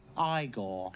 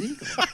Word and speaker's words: Igor: No, it's pronounced Igor. – But they told me it was Igor.